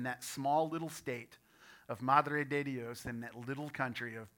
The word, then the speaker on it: Madre de Dios: That is the state of Madre de Dios, which uh, Pastor Scott perfectly pronounced.